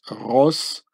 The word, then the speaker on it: Roscosmos: And the C here is an S. It says Roscosmos. It's quite easy. There's just a few letters that are different, you know. And so the Cyrillic Russian C gets pronounced as an S.